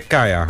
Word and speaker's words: Kaya: En de wethouder, die Kaya Amutaleb, zeg ik dat goed? – Nee, Kaya. – Kaya, oké.